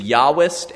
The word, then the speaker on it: Yahwist: The Yahwist, and we hear that as a Y, but Germans came up with it, so they spell it with a J.